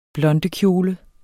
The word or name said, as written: blondekjole